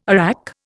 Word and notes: arrack: Indian

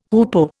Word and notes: hoopoe: Indian

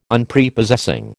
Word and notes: Unprepossessing: American English

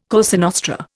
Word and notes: Cosa Nostra: Indian

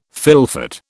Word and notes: fylfot: British English

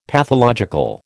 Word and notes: pathological: American English

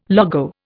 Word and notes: logo: Indian

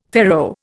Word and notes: faro: Indian

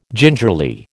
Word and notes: gingerly: American English

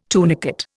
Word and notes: tunicate: Indian

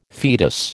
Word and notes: foetus: American English

